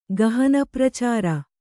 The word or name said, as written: ಗಹನಪ್ರಚಾರ